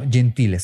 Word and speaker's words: Gentile: Después otro aguas me acuerdo, fue cuando empecé a ver a Nate Gentile, que tuve ahí, ah, no, Gentile, se pronuncia Gentile porque es italiano.